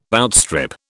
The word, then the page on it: outstrip: British English